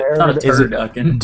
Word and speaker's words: turducken: It's not a turducken. – Is it turducken? Do you say, like, is it phonetically, is it like turducken? Like, is it the duckin that you really hit? – It's the duck. Turducken.